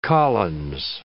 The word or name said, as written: Collins